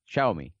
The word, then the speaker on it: Xiaomi: Tilde la O. – ¿Vale? La sílaba tónica es la O. Xiaomi.